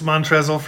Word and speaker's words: Montrezl Harrell: I knew it's Montrez, but I'm reading off the computer. Montrezl. I know it's Montrez. Montrez Harrell. – It's Montrezl for Shizzle.